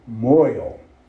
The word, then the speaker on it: mohel: And of course, the Hebrew word is M-O-H-E-L. And I always thought it was pronounced Mohel. But it's not pronounced Mohel. That letter H takes on a different kind of pronunciation. It's called Moyel.